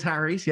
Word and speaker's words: Harries: It is Harries, yeah.